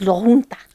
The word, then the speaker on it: La Junta: He lived in La Junta, Colorado. – La Junta, Colorado. – That's how you pronounce it. It's La Junta.